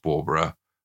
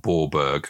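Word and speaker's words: Bawburgh: Now, the town is spelt B-A-W-B-U-R-G. So I was very disappointed to learn that it was pronounced Borborough and not Borberg, because it sort of sounded a bit like a Scotsman exclaiming something. – Borberg. – So it's Borborough.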